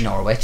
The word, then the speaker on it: Norwich: Or as the Americans say, Norwich. – Norwich. It's like Woolwich.